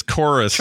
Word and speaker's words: Chorus: I call it Corvus. That's not correct. – It's Chorvs. – It's Chorus. – Chorvs. – Oh, I wrote Chorvs, didn't I? Yeah. It's supposed to be Chorus. And that's how they say it, but they spell the U or the U in the logo as a V, and it annoys me.